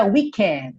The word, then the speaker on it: wicked: Uma palavra que termina em ed e o é pronunciado. Mas por quê? Isso aqui não é um verbo no passado, tá bom? É o adjetivo wicked e se pronuncia. Pode caprichar no é wicked, tá? Não precisa falar wicked. O E existe, tem som. É a revolução do E aí nessa palavra.